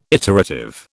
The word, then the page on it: iterative: British English